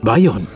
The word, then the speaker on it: Bayon: Als Autoname hat er allerdings zwei Buchstaben am Ende verloren, spricht sich aber genauso aus. Bayonne.